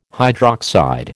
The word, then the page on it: hydroxide: American English